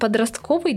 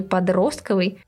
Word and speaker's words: подростковый: Вариант «подростковый» долгое время считался ошибочным. Словари указывали в качестве единственно верного ударения на второй слог – «подростковый». Несмотря на то, что словари настаивали на варианте «подростковый», большое количество людей говорило, говорит и будет говорить «подростковый», и словари сдались.